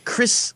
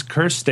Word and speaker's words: Krist: And with us, our special guest for the day, Krist Novoselic. That's how he told me to pronounce it. You know, pronounce the T, too?